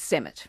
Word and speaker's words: Semut: Well, I'm thinking it's Semut. – Semut? It's not Semu or something like that. It's spelled S-E-M-U-T.